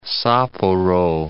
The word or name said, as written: Sapporo